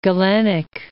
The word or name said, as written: Galenic